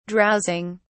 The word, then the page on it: drowsing: British English